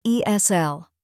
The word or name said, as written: ESL